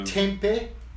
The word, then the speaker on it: Tempeh: Tempeh, that's another one. Yeah, not Tempe train station.